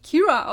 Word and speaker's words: Kyra: Und ich habe es auch im, wobei die kommt in allen Büchern vor, aber die wird auch eigentlich Kira ausgesprochen, aber sie wird Kyra geschrieben.